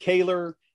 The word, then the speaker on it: Koewler: K-O-E-W-L-E-R. – So it's pronounced Kaler. It looks like Kuvler back in the old country, but as we know, Americans don't do the old country language, any of them, very well.